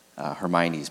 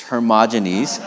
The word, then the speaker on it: Hermogenes: I almost want to say Hermione's, but it's Hermogenes.